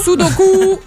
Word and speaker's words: Sudoku: Mal prononcé, hein? – Sudoku. – Voilà. C'est ça qu'il faut dire. – Sudoku.